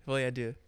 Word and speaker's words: Folie à Deux: Is that how you pronounce it? À Deux? – Folie à Deux. – Folie à Deux. – Yeah, that's right.